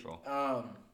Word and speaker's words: Tuchel: We looked up the pronunciation. It's Tuchel. – It's Tuchel.